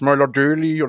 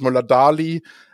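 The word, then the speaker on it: Møller Dæhli: auch mit drei Toren und dann natürlich noch Mats Möller-Döli oder Mats Möller-Dali, äh, muss man so ein bisschen nuschelig aussprechen, habe ich gehört, äh, die norwegischen Namen.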